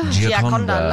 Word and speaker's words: Gioconda: Gioconda? – Gioconda.